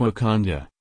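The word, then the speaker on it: Wauconda: Today I learned there is a suburb of Chicago called Wakanda, pronounced like Wakanda,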